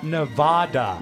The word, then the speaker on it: Nevada: Don't focus on the focus on the vat. Nevada. – Nevada.